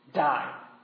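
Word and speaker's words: die (δεῖ): It's interesting, ironically, that the Greek word for translated, he must, is pronounced die.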